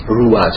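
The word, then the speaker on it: Ruah (רוח): 这个灵的本意是 Ruah，它的这个大概的希伯来的发音是这样 Ruah 这样。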